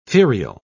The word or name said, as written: ferial